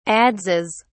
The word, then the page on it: adzes: British English